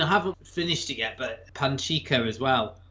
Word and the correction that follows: Pachinko: So you go, Pachinko.